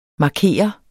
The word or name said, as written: markeret